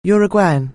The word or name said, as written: Uruguayan